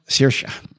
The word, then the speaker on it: Saoirse: Where Sirazi is pronounced Shorsha or something like that. – Saoirse.